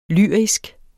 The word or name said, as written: lyrisk